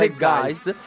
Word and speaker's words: Zeitgeist: documentario Zeitgeist o Zeitgeist, non so come si pronuncia, Zeitgeist.